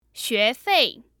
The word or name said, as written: xuefei